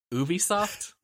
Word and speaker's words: Ubisoft: It's time for us to talk about is it Ubisoft or Ubisoft? I feel like I always say it wrong. – No, what are you going to say? And then I'll tell you whether you're right. – I'm going to say Ubisoft. – Yeah, that's right.